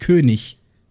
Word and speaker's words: König: Die, die Endung IG ist im Deutschen immer ich. Echt? Ja. Das ist Hochdeutsch. Ist immer ich. Das heißt König.